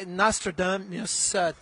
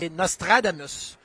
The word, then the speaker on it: Nostradamus: Because in French, we put the emphasis on the different syllable, Nostradamus, and not Nostradamus.